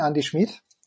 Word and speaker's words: Andi Schmidt: Bitte weich aussprechen, Andi Schmidt.